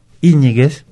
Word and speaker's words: Íñiguez: Y dado que su pronunciación es Íñiguez, por ejemplo, otra vez, es una palabra esdrújula y necesita el acento.